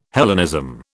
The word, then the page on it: Hellenism: British English